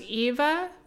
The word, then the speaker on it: Yva: There's a song called Pink Lemonade by, I think it's pronounced Eva, Y-V-A, Pink Lemonade. There's a song called Sad Saturdays by Joba, I believe, J-O-B-A.